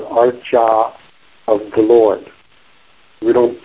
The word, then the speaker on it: Archa: You're used to saying Acharya, but then you notice there's no second R, so it's, the word is within the Archa. The word Archa, A-R-C, long A, Archa, the deity is named for the deity form, a form of the Lord for worshiping.